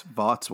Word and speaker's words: Vought: Vought's wife. – What? – You said Voight. – Voight. I've been pronouncing it wrong. Vought.